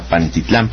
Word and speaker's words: Pantitlán: Ahora Pantitlán pronunciamos, ¿verdad?, ya castellanizado. Y esta es la fecha mexicana con la que iniciamos Del Campo y de la Ciudad.